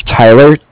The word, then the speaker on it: Tyler: My name is Tyler, T-Y-L-E-R. And like I said, I'm calling with Cardiff. It's spelled C-A-R-D-I-F-F, as in Frank Frank.